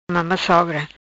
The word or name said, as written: Mama sogra